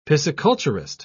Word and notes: pisciculturist: British English

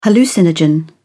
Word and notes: hallucinogen: British English

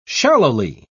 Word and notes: shallowly: British English

